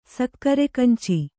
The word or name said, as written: ಸಕ್ಕರೆಕಂಚಿ